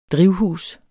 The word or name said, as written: drivhus